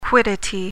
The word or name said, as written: quiddity